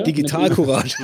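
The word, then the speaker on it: Digitalcourage: Ja, Digital Courage oder Digital Courage? Wie wird es ausgesprochen? – Digital Courage. – Digital Courage, alles klar. Okay. Tatsächlich nicht Sprache. Wie Zivilcourage, daran sind wir angelehnt, aber eben jetzt im Digital.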